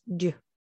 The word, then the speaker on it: Dieux: And for those who don't speak French, her brand is spelled D-I-E-U-X. So that's du, right? I said it right.